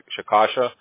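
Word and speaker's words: Chickasha: I'm going to say that wrong again. Shikasha? – Yeah, that's it, yeah.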